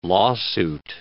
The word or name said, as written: lawsuit